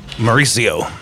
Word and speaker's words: Mauricio: Let's have you do it. M-A-U-R-I-C-I-O. – Mauricio. – Mauricio. Thank you.